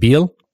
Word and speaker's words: Biel: Is it Biel? – Yeah, that's true. – So I was in there. I was actually at the Biel Curling Club. I did some schools back in the early 1990s in Biel.